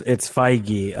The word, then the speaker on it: Feige: No, it's Feige. I'm trying to like... – I listened to like a pronunciation thing too, and I was like, no, that can't be right. – Yeah, you're pronouncing it like the Greek yogurt commercials that Bobby Flay does.